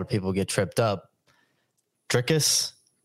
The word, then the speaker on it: Dricus: So we emphasize the last syllable, and it is Dricus.